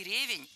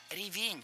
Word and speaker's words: ревень: Начальная форма не ревень, а ревень. Ударение в этом слове падает на второй слог.